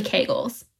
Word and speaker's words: Kegels: I was speaking to her and I was telling her about it, and I was like, oh, and they do Kegels for you. And she's like, it's actually Kegels.